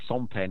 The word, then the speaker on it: Sempé: It's Sempé and it's Lambert, I think.